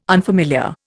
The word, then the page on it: unfamiliar: Indian